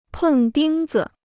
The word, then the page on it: 碰钉子: pèng dīng zi